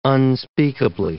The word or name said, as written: unspeakably